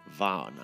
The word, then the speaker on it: Vaughan: So, Vaughn, ladies and gentlemen, it's pronounced and spelled, V-A-U-G-H-A-N, Vaughn, okay?